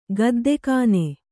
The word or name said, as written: ಗುದ್ದುಗಣ್ಣು